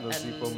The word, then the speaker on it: Nozipho: On Instagram, my name is Nozipho, which is Nozipho, silent H for pronunciation. – Yep. – Nozipho. – Yep. – McLean, McLean. And that's my Instagram handle. – At Nozipho McLean.